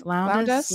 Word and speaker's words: Lowndes: How do you say her last name? – I think it's Lowndes. – Lowndes. Lowndes. I've never liked her better than I did in this movie.